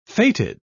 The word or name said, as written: fated